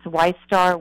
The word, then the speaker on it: Wistar: I think that's how you say it. It's Wistar, Wistar rat. – Rats, Wistar rat.